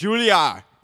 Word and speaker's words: Juilliard: It's Juilliard. The D is silent.